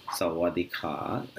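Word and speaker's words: Sawadika (สวัสดีค่ะ): So as far as pronunciation is concerned, for greeting people, the way they say hello is Sawadika. Sawadika. And Sawadika is for girls. When a girl is saying Sawadika. For guys, it says Sawadika. Sawadika.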